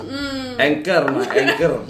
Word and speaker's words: Anchor: lihat aplikasinya hancur gitu ya. – Hancur? Apanya hancur? Anchor. Anchor mah Anchor. Hancur. Iya kan anchor.